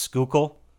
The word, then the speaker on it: Schuylkill: I also have not convinced my girlfriend that the word is actually pronounced Schuylkill.